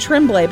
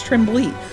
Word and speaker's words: Tremblay: Because I said Tremblay, but it's Tremblay. Lori Tremblay.